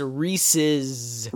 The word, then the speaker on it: Reese's: Yeah, that's a pre-existing name that, like, you can't just change that pronunciation to Reese. Okay, that's one. There's an S at the end of it, number two. Reese's.